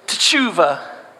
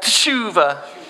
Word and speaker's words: teshuvah: And it's teshuvah. You wanna try that? Teshuvah. Yeah, we just forget the E and we're just going straight for it.